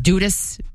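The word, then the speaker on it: Dudus: Now, it's D-U-D-U-S, pronounced Dudas.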